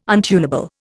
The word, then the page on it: untunable: Indian